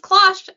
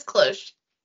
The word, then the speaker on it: cloche: Sorry, I Googled cloche to be like pronunciation and Google says British pronunciation is cloche, American pronunciation is cloche.